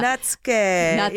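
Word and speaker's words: netsuke: Can you tell us what Natsuki is? Am I saying that right? – Oh, yes. Yeah. Natsuke. – Yeah, okay, Natsuke. So I'm saying it wrong.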